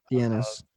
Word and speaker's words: Dienes: We do have Bay Village Police Department on record in the papers saying that they wanted to speak with Frank Denis, or Denise, if I'm saying his name right, D-I-E-N-E-S. – Dennis. Frank Dennis. – Yeah, Frank Dennis, and in relation to Amy's case.